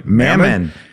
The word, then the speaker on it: Mammon: Mammon, can I say that right? – Mammon.